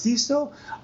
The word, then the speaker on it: Cecil: Uh, I've always heard to pronounce Ceso, um, but the Brits pronounce it Cesso because it's a common name over there.